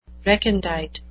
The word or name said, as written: recondite